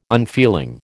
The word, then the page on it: unfeeling: American English